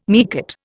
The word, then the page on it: meerkat: Indian